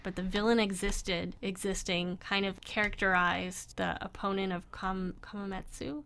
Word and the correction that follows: Kumatetsu: Yeah, Kumatetsu. – Yeah. – Like Kuma, like bear, and then Tetsu. – Yeah. – That's how you say it.